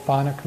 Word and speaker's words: chthonic: Does everyone know what thonic means? It's spelled C-H-T-H-O-N-I-C. And it means it's relating to the gods of the underworld.